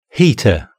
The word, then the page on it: heater: British English